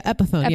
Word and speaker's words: Epiphone: But when people started buying guitars in April, they ended up reopening the guitars, relaunching the Epiphone. I think that's how you pronounce it. – Yeah, Epiphone.